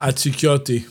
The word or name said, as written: Atsikoti